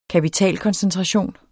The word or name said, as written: kapitalkoncentration